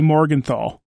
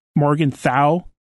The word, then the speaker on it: Morgenthau: Like this Flag Smasher leader, Carly Morgenthau. Morgenthau? I don't know how to pronounce that name without putting an L at the end.